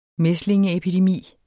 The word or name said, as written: mæslingeepidemi